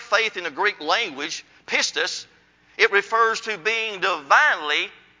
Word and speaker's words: pistis: If you look up faith in the Greek language, the word faith is spelled like this. P-I-S-T-I-S. P-I-S-T-I-S, which is pistis.